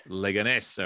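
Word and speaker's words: Leganés: which is CD Leganes, I guess. Am I saying that right? – Leganes. – Okay.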